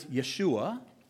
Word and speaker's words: Yeshua: In Hebrew, the name of Jesus, this phrase, Yahweh saves, is pronounced, some of you are very familiar with this, it's pronounced as Yeshua.